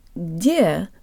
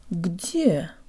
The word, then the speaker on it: где: If we were speaking English, the G might almost disappear into the D, like in the English word begged. But this does not happen in Russian. It's not где, but где.